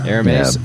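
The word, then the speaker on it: Hermès: Isn't it Hermes? Isn't that how we pronounce that word? – That's how we should pronounce it. Yes. – Say it again, so I've got it right. – Hermes. – Yeah, okay, that's probably more correct.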